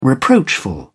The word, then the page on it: reproachful: British English